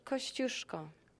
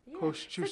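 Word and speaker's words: Kościuszko: She watches the movie, she peers into the exhibit, and then she tells me I've been pronouncing the man's name wrong. – Tadeusz Kosciuszko. – Kosciuszko.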